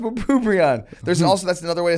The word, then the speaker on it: bupropion: He was on Bupropion.